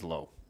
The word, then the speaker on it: Lowe: So you have Lowe and Lau, even though it's spelled the same way. – Yeah, Brandon is pronounced Lau, and then Nate is Lowe.